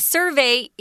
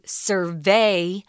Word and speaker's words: survey: A survey is the noun pronunciation, but you'll often hear people say survey as the verb form, so be careful with that.